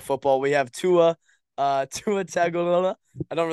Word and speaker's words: Tagovailoa: Well, it's better going Tagovailoa. Tua Tagovailoa. There you go. – Tagovailoa.